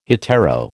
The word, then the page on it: hetero: American English